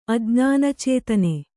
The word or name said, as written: ಅಜ್ಞಾನಚೇತನೆ